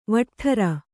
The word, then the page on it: ವಟ್ಠರ: vaṭṭara